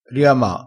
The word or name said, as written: riama